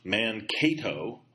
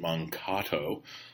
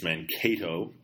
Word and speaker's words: Mankato: This from a listener, Mankato, where several Dakota Indians were hung during the Civil War, as we mentioned a couple weeks ago, is pronounced Mankato. I had pronounced it Mankato. It's Mankato.